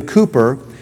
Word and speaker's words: Cowper: William Cowper, sometimes I've heard it pronounced William Cooper, was the man that wrote, "There is a fountain filled with blood."